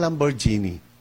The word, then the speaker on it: Lamborghini: Oh, Lamborghini. Habi ng isang Kristiyano, darating ang araw, makakabili ako ng Lamborghini. Mahal yun, brother, no? Million yan, ano? Sabi ng isang kasama niya, anong spelling ng Lamborghini?